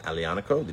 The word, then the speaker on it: Aglianico: It is made from the Aglianico grape, Aglianco. Aglianico. The G is silent.